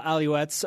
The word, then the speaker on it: Alouettes: Austin Colley responded via Twitter yesterday to reports that he had signed with the Montreal Alouettes of the CFL. – Way to say it correctly.